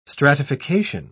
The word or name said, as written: stratification